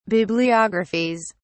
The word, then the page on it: bibliographies: British English